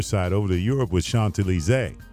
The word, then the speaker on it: Champs-Élysées: And you said it kind of, you said it right, man, because, you know, a lot of times people say Champs-Élysées. And one of my French friends, you know, Alex Bougnon. – Sure. – So he pulled me aside, he's like, look, man, I got to teach you how to say this. He says, the Champs-Élysées.